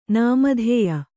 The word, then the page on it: ನಿಮಿತ್ಯ: nimitya